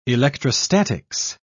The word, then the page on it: electrostatics: British English